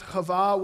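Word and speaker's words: Hava (חוה): The, the, the, uh, the name Eve is pronounced Hava, which is similar um, to the, the um, Aramaic word uh, Hiwa, which is serpent.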